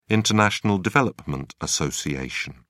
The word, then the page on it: international development association: British English